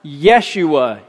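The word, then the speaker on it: Yeshua (ישוע): The angel would have said, name him Joshua, which in Hebrew would have been pronounced Yeshua. Yah for Yahweh and Shua for saves.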